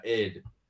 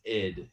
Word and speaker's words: id: I don't know if you uh, listen to uh, id, um, which by the way, it's pronounced id. It's not ID. Everybody thinks it's ID.